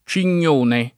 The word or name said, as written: Cignone